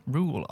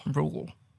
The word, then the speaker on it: Reuel: Rule. R-E-U-E-L. – Rule. – Rule.